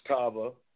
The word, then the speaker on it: Tava: I'm sorry for pronouncing it wrong. It's Tava. You know, it's Tava Lifestyle, definitely.